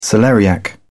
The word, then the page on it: celeriac: British English